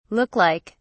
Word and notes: look like: British English

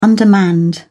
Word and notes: undermanned: British English

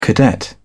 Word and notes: cadet: British English

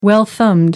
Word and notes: well thumbed: American English

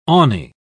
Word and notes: awny: British English

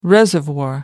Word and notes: reservoir: American English

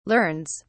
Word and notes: learns: British English

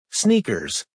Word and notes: sneakers: American English

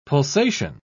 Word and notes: pulsation: British English

